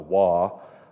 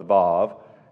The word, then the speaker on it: Vav: It says W-A-W if you're reading from the ESV above it. In the Hebrew pronunciation, it would be the Vav, not the Wah, but the Vav.